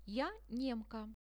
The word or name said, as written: ja nemka